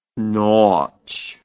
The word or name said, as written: nawch